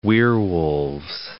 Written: werewolves

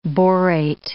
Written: borate

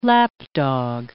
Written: lapdog

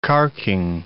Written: carking

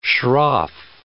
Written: shroff

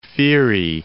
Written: Pherae

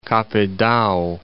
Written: Kapidagi

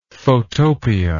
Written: photopia

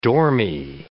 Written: dormie

